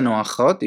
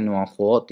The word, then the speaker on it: 暖和: 暖和っていうのは暖かいということです。この和ですね。和ではありませんね。発音に注意ですね。まあ、人によっては暖和っていう人もいるんですけど、まあ、一般的には暖和って言いますよ。